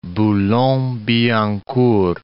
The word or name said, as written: Boulogne-Billancourt